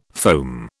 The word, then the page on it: foam: British English